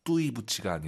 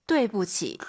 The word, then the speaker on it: 对不起: 아이유 사이에 오, 그리고 유, 아이 사이에는 에, 뚜이 부치가 아니라. – 对不起.